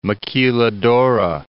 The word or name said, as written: maquiladora